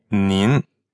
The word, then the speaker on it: ni: So the difference here is ni, just the simple you in pinyin is spelled N-I. – And it's third tone. – Right. And nin is N-I-N. – And that's second tone.